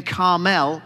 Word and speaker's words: Carmel: A certain man in Maon, who had property there in Carmel, that's how they taught me to pronounce it, my guide in Israel, says that Carmel, he was very wealthy.